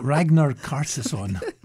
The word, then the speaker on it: Ragnar Kjartansson: There's a double pass to the National Gallery of Victoria's Ranier Kjartansson. – Pronunciation's right next to the word. – Ragnar Kjartansson.